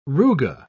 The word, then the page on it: ruga: British English